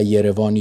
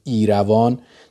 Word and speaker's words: ایروان: یروان، یا با تلفظ خودمون در ایران ایروان، در ارمنستان حضور و موفقیتش و جوایزش تداوم داشت.